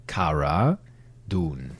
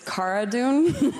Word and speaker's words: Cara Dune: Wie wird sie jetzt eigentlich ausgesprochen? Ich weiß noch, die Schauspielerin Gina Carano hat auf einem Panel da gesessen und hat darauf bestanden, Cara Dune. – Her name, by the way, is Cara Dune.